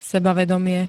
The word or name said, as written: sebavedomie